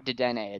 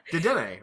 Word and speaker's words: Dedenne: But Dedenne, right? That's how you say it.